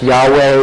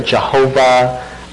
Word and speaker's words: Yahweh: In Hebrew, in English letters in Hebrew, it's YHWH, which sometimes you see pronounced Yahweh or Jehovah or something like that. Jehovah is a very anglicized form of that.